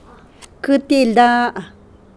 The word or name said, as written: Quialana